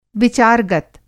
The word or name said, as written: विचार-गत